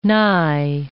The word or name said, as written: nigh